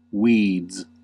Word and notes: weeds: American English

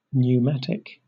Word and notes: pneumatic: British English

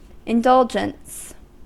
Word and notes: indulgence: American English

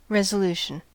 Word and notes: resolution: American English

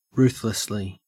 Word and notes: ruthlessly: Australian English

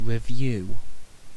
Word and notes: revue: British English

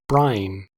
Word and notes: brine: American English

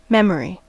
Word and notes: memory: American English

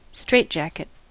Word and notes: straitjacket: American English

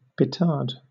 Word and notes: petard: British English

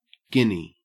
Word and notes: guinea: Australian English

